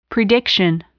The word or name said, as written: prediction